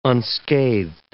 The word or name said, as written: unscathed